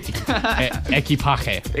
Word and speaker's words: equipaje: Equipaje. – Exactly, right? – Yeah. – That's what you got to say. Equipaje.